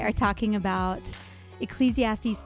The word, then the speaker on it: Ecclesiastes: Yeah, and I'm glad you pronounced that right, because I feel like I never pronounce Ecclesiastes.